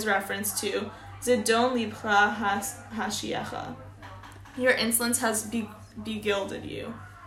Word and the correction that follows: beguiled: Beguiled? – Mm-hmm.